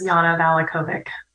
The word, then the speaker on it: Yana Valachovic: Um, Jana Volokovic. I'm, I'm sure I'm Jana. I'm mispronouncing your name. Can can you tell us uh, how to correctly pronounce your name? – Well, good morning, everyone in Radioland. My name is Yana Valachovic.